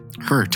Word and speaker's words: Hert: It is spelled H-E-R-T though. – Hurt.